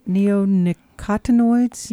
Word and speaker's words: neonicotinoids: Neonicotinoids? – Yes.